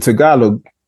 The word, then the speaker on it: Tagalog: The Tagalog, did I say that right? – Yeah, that's correct.